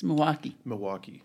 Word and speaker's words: Milwaukee: In the no, you don't pronounce the L. It's Milwaukee.